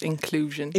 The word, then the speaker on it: InclUUsion: She also founded InclUUsion, is that the best way of pronouncing it? Yeah, or just inclusion.